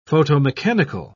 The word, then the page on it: photomechanical: British English